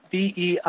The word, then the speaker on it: Beijing: Beijing.